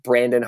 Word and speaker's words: Branden: would say especially Instagram at at Branden Harvey. My name is spelled a little funny; it's spelled with an e n.